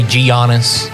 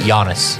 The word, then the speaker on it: Giannis: A lot of people say Giannis. – Yeah. – But Giannis.